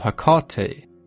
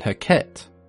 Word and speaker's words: Hecate: The most common pronunciations that I came across were Hecate, Hecate, Hecate, and Heket, which all sound pretty reasonable to me. But if we take a look at the way it's pronounced in Greek, it's Ergati, which could easily be translated to both Hecate and Hecate, so I guess it comes down to personal preference and which part of the world you're from.